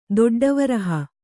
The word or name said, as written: ದೊಡ್ಡವರಹ